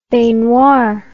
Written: baignoire

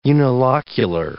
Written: unilocular

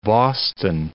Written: Boston

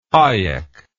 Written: Hayek

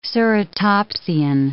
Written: ceratopsian

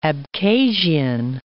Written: Abkhazian

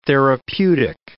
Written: therapeutic

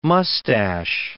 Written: MUSTACHE

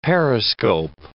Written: periscope

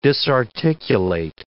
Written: disarticulate

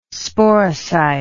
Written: sporocyte

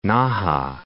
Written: Naha

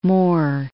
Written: Moore

